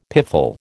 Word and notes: piffle: American English

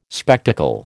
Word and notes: spectacle: American English

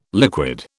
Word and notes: liquid: British English

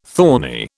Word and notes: thorny: British English

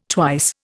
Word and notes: twice: Indian

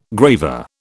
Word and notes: graver: British English

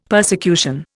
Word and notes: persecution: Indian

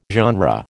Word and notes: genre: American English

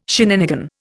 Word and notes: shenanigan: Indian

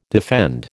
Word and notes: defend: American English

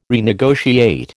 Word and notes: renegotiate: American English

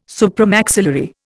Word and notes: supramaxillary: Indian

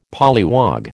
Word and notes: polliwog: American English

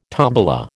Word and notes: tombola: American English

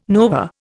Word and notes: nova: Indian

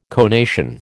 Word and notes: conation: American English

